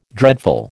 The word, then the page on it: dreadful: American English